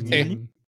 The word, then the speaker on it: Ghibli: ¿saben cómo se pronuncia en ¿sí, ¿sí conocen este estudio de animación japonés Ghibli? Las películas Ghibli. Sí, sí, sí, claro.